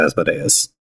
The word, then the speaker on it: Asmodeus: From a Greek pronunciation standpoint, it's always the third syllable from the end, which is why I say Asmodeus.